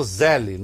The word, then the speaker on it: Zelle: Ele chegou a falar de um sistema de pagamento americano, que ele até chamou Zelle, não é Zelle, é Zell. Se escreve Z-E-L-L-E, mas é Zell que se fala.